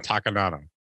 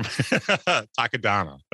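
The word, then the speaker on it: Takodana: Yeah, Maz Kanata on Takadana. I didn't say it right that time. Takadana. There we go.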